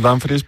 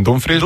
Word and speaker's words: Dumfries: Sí, porque aquí al principio decíamos Dumfries, pero estamos escuchando a los compañeros que dicen Dumfries.